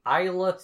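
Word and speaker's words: Islay: We are drinking Isla Storm, which is apparently the correct pronunciation of that region.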